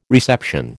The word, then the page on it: reception: American English